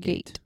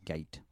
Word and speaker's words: gait: I mean, I have a front gait. – It's a different kind of gait. G-A-I-T, not G-A-T. – That sounds... the way it's spelled, it seems like it should be gate.